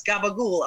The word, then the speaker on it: capicola: I remember the first time I was in upstate New York and I said capicola, and somebody goes, "No, it's gabagool."